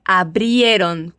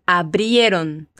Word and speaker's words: abrieron: Cuidado com esse abriram, é abrieron, com IE, abrieron.